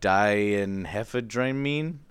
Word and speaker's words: diphenhydramine: Diphenhydramine? How do you say it? No, that's diphenhydramine. – Diphenhydramine. – PH is an F. Diphenhydramine.